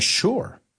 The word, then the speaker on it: sure: So if you say it correctly, you're saying, sure. Ese, ese suena como SH.